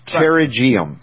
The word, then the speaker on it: pterygium: Yeah, it's like a silent P, but it's pterygium. and others might pronounce it differently,